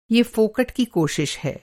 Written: फोकट

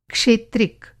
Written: क्षेत्रिक

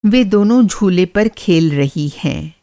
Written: झूला झूलना

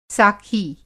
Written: साखी

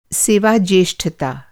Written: सेवा

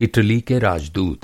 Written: राजदूत

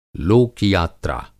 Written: लोक-यात्रा

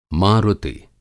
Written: मारुति

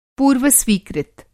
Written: पूर्व-स्वीकृत

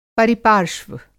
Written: परिपार्श्व

